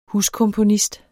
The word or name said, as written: huskomponist